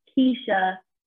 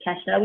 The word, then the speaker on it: Kesha: most people say it's not Keisha, not Ketchup, it's Kesha, which is kind of straightforward.